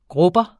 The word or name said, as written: grubber